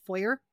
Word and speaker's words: foyer: Did you Americans say foyer? – Oh, the front foyer. – But it sounds foyer. – Foyer. – We say foyer. – Foyer. – It's French.